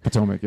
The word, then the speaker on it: Potomac: Potomac, yeah.